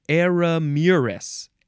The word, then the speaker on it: Eremurus: The last word is eremurus. Eremurus. E-R-E-M-U-R-U-S. Noun from 1829. The plural is eremuri. This is any of a genus, which is also Eremurus, of perennial Asian herbs of the lily family that produce tall racemes of showy blooms.